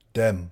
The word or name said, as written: dem